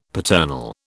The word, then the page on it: paternal: British English